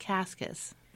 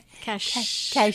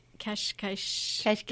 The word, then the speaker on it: Cascais: It's not pronounced Cascas. It's Cashish. – Kashkash. Yeah, the S has like a shish sound.